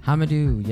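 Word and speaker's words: Hamidou: Hamadou, yes.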